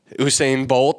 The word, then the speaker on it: Usain Bolt: That means hopefully Usain Bolt.